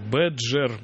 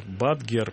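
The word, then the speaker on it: Badger: Да, нет, «Бэджер», «Бадгер» пишется, да.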